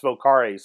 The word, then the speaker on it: vocare: Actually, is, it's pronounced vocare since it's Latin.